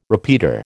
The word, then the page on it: repeater: American English